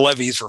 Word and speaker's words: Levy: It's spelled like Levi, but it's pronounced Levy.